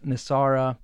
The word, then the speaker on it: NESARA: and he's gonna enact Nasara, and that's spelled N-E-S-A-R-A.